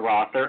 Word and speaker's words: Rother: Actually, my name is pronounced Rother, R-O-T-H-E-R, Rother, and I am movie guy 24/7. – Yeah, Rother, okay, bad Carl. Remember pronunciation, bad Carl.